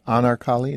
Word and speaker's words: Anarkali: Well, tell me about this bazaar, the Anarkali, is that how you say it? – That's right.